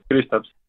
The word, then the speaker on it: Kristaps: He dicho bien tu nombre, Kristaps, ¿cómo se pronuncia tu nombre? – Sí, está muy bien, sí, Kristaps.